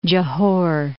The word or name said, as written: Johor